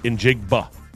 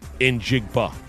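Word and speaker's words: Njigba: Sam Darnold, two touchdowns, including a nice 61-yarder to Jackson Smith in Jigba. In Jigba. In Jigba. See how I said that? That's how you pronounce his last name.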